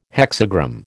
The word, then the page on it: hexagram: American English